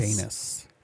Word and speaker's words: Dainis: D-A-I-N-I-S, Danis. Danis.